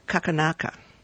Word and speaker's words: Xakanaxa: It's spelled X A K A N A X A, but they say it's pronounced Kakanaka.